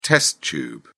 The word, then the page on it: test tube: British English